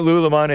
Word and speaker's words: lululemon: I thought it was Lululemon until I heard JLP's producer pronounce it correctly. Somehow I didn't see that third L and that second U. It's not Lululemon, it's Lululemon.